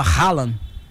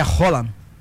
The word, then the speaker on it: Haaland: Até eu mesmo falava Haaland. – Haaland. – Mas é Haaland.